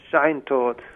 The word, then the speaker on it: Scheintod: Scheintod. – The Scheintod.